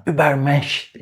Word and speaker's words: Übermensch: Üst insan, übermensch şeklinde telaffuz ediliyor.